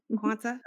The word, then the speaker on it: Quanta: Okay, a TEDx Talk, Hollings Cancer Center Changing What's Possible campaign, and Quanta Magazine. Did I pronounce that correctly? – You did. – Quanta, okay.